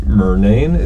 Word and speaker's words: Murnane: So I understand that you've recently published some major results in collaboration, I think, with Professors Margaret Murnane, is that how I'm saying it right? – Correct.